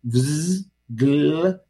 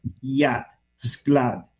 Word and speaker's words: Vsevolod: Vsevolod. Vsevolod. Vsevolod. Vsevolod.